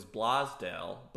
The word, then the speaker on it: Blasdel: Yeah, it's a long A, and it, you know, it's tricky. It tricks a lot of people. There used to be an I in there to make it more clear that it was a long A sound, because the way it's spelled, for those listening, B-L-A-S-D-E-L, it does look like it would be Blasdel.